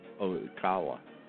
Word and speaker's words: Ocala: So yeah, Maggie C told us it's pronounced Ocala.